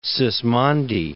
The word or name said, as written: Sismondi